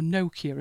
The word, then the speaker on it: Nokia: Uh, the two main industries were Nokia, or Nokia as some pronounce it, and paper.